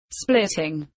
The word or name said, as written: splitting